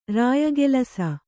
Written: ರಾಯಗೆಲಸ

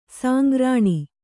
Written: ಸಂಗ್ರಾಣ

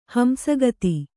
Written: ಹಂಸಗತಿ

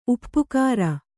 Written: ಉಪಕಾರ

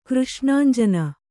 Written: ಕೃಷ್ಣಾಂಜಿನ

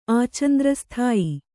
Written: ಆಚಂದ್ರಸ್ಥಾಯಿ

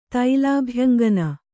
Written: ತೈಲಾಭ್ಯಂಗನ

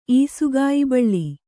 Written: ಈಸುಗಾಯಿಬಳ್ಳಿ

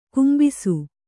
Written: ಕುಂಭಿಸು

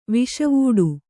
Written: ವಾಸಿವಟ್ಟ

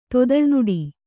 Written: ತೊದಲ್ನುಡಿ

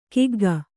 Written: ಕಗ್ಗ

